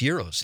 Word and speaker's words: gyros: It's pronounced euros.